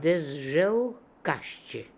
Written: dezjelkasheh

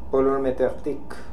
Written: Polormedekhtic

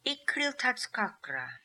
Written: Ikriltazkakra

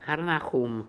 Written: Harnahoom

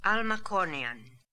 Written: Almacornian

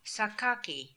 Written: Sakaki

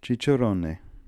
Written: cicerone

